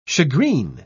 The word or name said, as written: shagreen